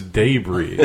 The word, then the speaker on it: debris: Like, I got some debris with my pork, and I'm like, what the fuck is debris? And it's like, oh, it's debris. They just don't know how to say it, even though it's a French word. – Wrong emphasis on the wrong syllable.